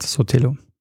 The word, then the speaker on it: Sotelo: Und der heißt Leopoldo Calvo Sotelo und ich sage einmal Soleto statt Sotelo.